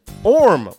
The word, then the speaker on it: Orm: Okay, Alan from Ormworks, Orm, like spelled like worm without the W.